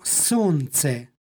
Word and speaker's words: solnce: Tako da umesto solnce, kako piše, izgovaramo sonce.